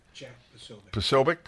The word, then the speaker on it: Posobiec: P-O-S-O-B-I-E-C. B-I-E-C. – Jack Posobiec.